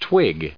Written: twig

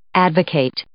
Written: advocate

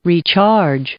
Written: recharge

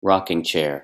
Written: rocking chair

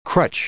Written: crutch

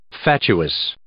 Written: fatuous

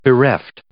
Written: bereft

